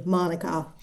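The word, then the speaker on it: mānuka: Well, if you're from New Zealand, it's definitely Manuka.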